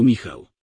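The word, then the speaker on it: Michal: Nie wiem, jak się to wymawia po angielsku, więc powiem po prostu Michał, tak jak po hebrajsku Michał.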